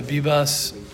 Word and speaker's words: Bibas: We're dedicating the shiur to the, who is it, the Bibas, that's how you say it, the Bibas family.